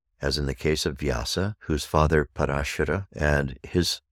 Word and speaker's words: Vyasa: Now then, Vyasa, 5,000 years ago, one of the great rishi, was not known for being a seer of the Veda. He was known as Veda Vyasa. Veda Vyasa. Vyasa is spelled V-Y-A-S-A.